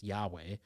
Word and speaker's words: Yahweh: which we pronounce as Yahweh, echoes the sounds we make when we inhale and exhale.